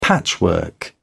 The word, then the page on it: patchwork: British English